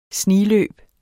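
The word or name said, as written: SNIGLØBENE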